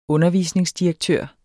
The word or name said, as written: undervisningsdirektør